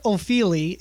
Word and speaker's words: Ofili: Well, I feel like you're not wrong because Ofili sounds Italian as opposed to British, but because it's spelled O-F-I-L-I.